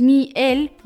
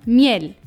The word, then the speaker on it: miel: Das bedeutet, dass Miel nicht so ausgesprochen wird, sondern Miel. Das heißt, die Betonung liegt auf der letzten Silbe.